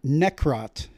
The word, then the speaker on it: Necrot: And I'm speaking with Sonny from the band Necrot. Is that the right pronunciation? – You got it, bud.